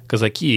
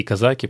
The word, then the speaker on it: казаки: с точки зрения норм русского языка, произношение может быть и казаки, и казаки, поэтому, наверное, сегодня мы будем скакать.